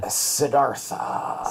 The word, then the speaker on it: Siddhartha: Siddhartha.